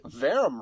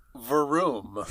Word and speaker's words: Verum: And on that note, people tend to say Verum Rex with a short U, like Verum, yeah. But the dev team actually pronounces it with a long U, like Verum.